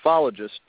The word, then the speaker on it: ufologist: Is it UFologist? Is that how it's pronounced? It's the ufologist. The ufologist. Okay, okay, okay.